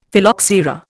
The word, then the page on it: Phylloxera: Indian